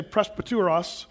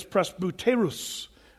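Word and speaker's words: presbyteros (πρεσβύτερος): The first word that's used, the Greek word is, some people say presbyteros, but if you want to pronounce it correctly, it's presbyteros.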